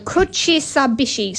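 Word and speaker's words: kuchisabishii: I just think that is the best word ever, although I don't think I'm pronouncing it right. Kushabishi. Jonathan's coming round to help. – Yeah, no, that's right. – Kushabishi.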